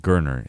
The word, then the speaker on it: Gardner: I learned how to pronounce his last name. Gardner. It's just a hard kind of G.